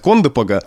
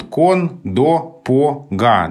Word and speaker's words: Кондопога: Русский язык вообще не любит в длинных словах ударение на краях слов. У нас тут кон, до, по, га, да, 4 слога. И в слове из 4 слогов ударение на первом слоге – это очень тяжело и сложно. И именно поэтому язык сам склоняет нас к ударению Кондопога.